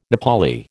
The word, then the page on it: Nepali: American English